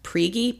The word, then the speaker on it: Prigge: So the family, the neighbors who she had went to, I believe their last name is pronounced Prigge, P-R-I-G-G-E.